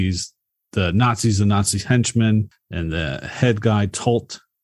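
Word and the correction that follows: Toht: Is it Tolt? Am I saying that right? – Tote. Yes. – Tote. Yes.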